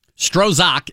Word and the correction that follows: Strzok: is his name. – That's not his name. How do you spell it? – S-T-R-Z-O-K. – So I think it's Strzok.